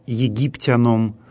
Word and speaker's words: египтянам: «Наведох я навел» — это аорист в форме первого лица единственного числа, «египтянам» — дательный падеж множественного числа от слова «египтянин». Это слово в церковнославянском языке произносится с таким ударением.